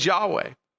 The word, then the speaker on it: Yahweh: it's actually a representation or a replacement for the divine name, YHWH. We sometimes pronounce it as Yahweh.